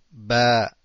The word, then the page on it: ب: Ba